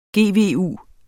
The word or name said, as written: GVU